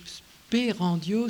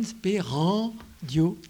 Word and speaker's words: Sperandio: Sperandio, c'est comme ça se prononce aussi, S-P-E-R-A-N, Sperandio, D-I-O, Sperandio.